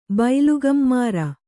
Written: ಬೈಲುಗಮ್ಮಾರ

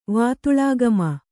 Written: ವಾತುಳಾಗಮ

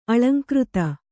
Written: ಅಲಂಕೃತಿ

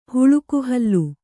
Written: ಹುಳುಕುಹಲ್ಲು

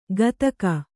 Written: ಗತಕ

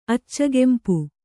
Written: ಅಚ್ಚಗೆಂಪು

